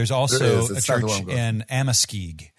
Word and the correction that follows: Amoskeag: Amoskeag, Amoskeag.